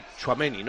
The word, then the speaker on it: Tchouaméni: A mí me gusta que hoy Tchouaméni, ¿no? ¿Lo he dicho bien? – Sí, muy bien. – Tchouaméni ya vaya cogiendo galones, vaya cogiendo minutos, se pueda ir asentando ahí.